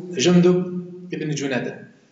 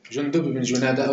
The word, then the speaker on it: جندب بن جنادة: جندب بن جنادة، جندب بن جنادة أو جندب بن جنادة بالكسر أو بالرفع أو بغيرها يعني فيها خلاف يعني في النطق يعني فيها.